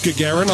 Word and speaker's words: Gagarin: Yuri Gagarin. – It's pronounced Gagarin.